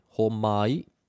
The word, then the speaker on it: homai: We have homai, which means pass me or give me, homai, three syllables, and hoatu.